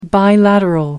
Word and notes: bilateral: American English

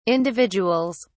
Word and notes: individuals: British English

